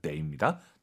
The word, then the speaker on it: 得: 수러, 즉 동사나 형용사 앞에 있으면 더가 아니라 데이입니다.